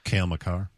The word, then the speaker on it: Cale Makar: Cale Makar. – Makar, the UMass defenseman, who's a freaking stud.